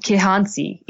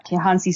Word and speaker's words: Kihansi: It's the Kinsanzi spray toad. Is that how you pronounce it? Um, Kihansi. Yeah, Kihansi spray toad. That's right yeah,